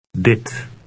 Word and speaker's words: dit: It has a short I sound that resembles the one in the English word hit. Read 13. – Dit.